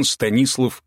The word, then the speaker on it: Станислав: Он Станислав Ковальский, с ударением в имени на «и».